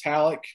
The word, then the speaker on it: Halicke: You can follow me on Twitter at Chris Halleck, last name spelled H-A-L-I-C-K-E.